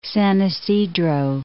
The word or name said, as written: San Isidro